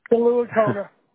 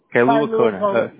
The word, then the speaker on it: Kailua Kona: And also, I just wanted to inform you or ask you, this is in Kailua Kona. Did I pronounce it right? So sorry for that. – Yes, Kailua Kona. – Kailua Kona.